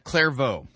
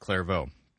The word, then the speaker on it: Clairvaux: Yep, Clairvaux. Not clairvoyant, but Clairvaux.